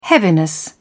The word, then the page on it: heaviness: British English